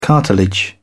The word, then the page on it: cartilage: British English